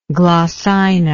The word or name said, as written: glossina